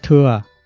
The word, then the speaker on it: thừa: Chân thẳng này là Phật thừa, chữ thẳng quen đọc là thừa.